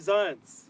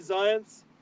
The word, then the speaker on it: Zionce: I said it right? – Zionce. – Okay, Shloime Zionce. So we have a correction, Shloime Zionce.